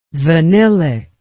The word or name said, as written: vanillic